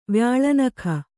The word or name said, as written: ವ್ಯಾಳನಖ